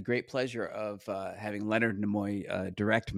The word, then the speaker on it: Nimoy: In 1985, I was directed by Leonard Nimoy, which I believe is the correct pronunciation. Nimoy. – Like Demi.